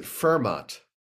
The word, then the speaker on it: Fermat: Well, I believe we would pronounce it Fermat Commerce, much like Fermat's last theorem.